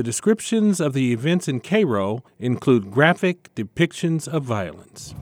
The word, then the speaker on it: Cairo: Cairo, yes, they pronounce it like the syrup.